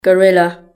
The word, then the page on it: gorilla: British English